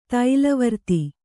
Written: ತೈಲವರ್ತಿ

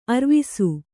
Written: ಅರ್ವಿಸು

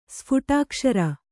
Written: ಸ್ಫುಟಾಕ್ಷರ

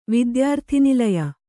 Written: ವಿದ್ಯಾರ್ಥಿನಿಲಯ

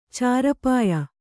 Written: ಚಾರಪಾಯಿ

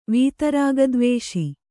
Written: ವೀತರಾಗದ್ವೇಷಿ

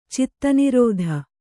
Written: ಚಿತ್ತನಿರೋಧ

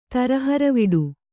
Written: ತರಹರವಿಡು

